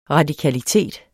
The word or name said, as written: radikalitet